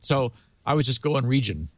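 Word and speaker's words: Regin: I've heard two pronunciations for this. Some are calling it Region. I've heard it pronounced Reagan. Which one do you use? – Well, it's R-E-G-I-N. So I think you can kind of go either way.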